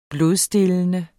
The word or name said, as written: blodstillende